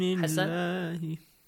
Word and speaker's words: اللَّهِ: مِن دُونِ اللَّهِ مِن دُونِ اللَّهِ أحسنتم أحسنتم دُونِ اللَّهِ The word Allah is pronounced with an A sound.